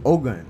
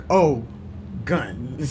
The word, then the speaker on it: Ogun: Yeah, right, O-G-U-N. Right. So basically, to get people to pronounce it well, I was like, it's like, oh shit, I see a gun. So, yeah. Oh, gun. So basically,